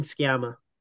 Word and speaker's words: Sciamma: Celine Sciamma.